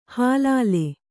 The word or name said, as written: ಹಿಲ್ಲೋಲ